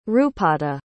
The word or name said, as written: rupatta